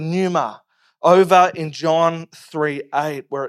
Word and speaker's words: pneuma: So the P is silent, it's pneuma.